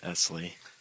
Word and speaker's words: Ezley: It's an S, not a Z, though, just Ezley.